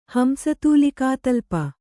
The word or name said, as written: ಹಂಸತೂಲಿಕಾತಲ್ಪ